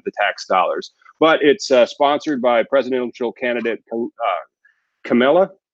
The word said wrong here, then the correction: Kamala: How do you pronounce Harris's first name? – Kamala, I think it is. – Kamala Harris. All right.